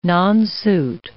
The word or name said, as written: nonsuit